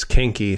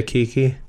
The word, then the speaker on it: Kinky: Did I say Kiki? – No. – Kinky. – No, I'm asking. You said it correctly if it's Kinky. – Yeah, Kinky. – With an N in it.